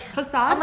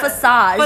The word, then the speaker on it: facade: Like facade. Oh my god. Did I say it right? – Yes. Yes. Not facade. Facade.